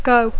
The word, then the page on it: go: English